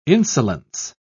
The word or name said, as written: insolence